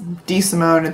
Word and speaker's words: DeSimone: It's DeSimone. It's the most complicated name for no good reason. So. – I like to pronounce it DeSimone.